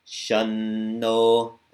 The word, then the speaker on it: Shanno: It's not always pronounced like an M. Like in this first case, it's going to be pronounced like an N. Shanno Mitra.